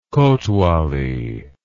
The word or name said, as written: kotwali